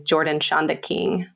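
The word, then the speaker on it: Jordan Schanda King: And then on Instagram, it's my name, Jordan Shonda King.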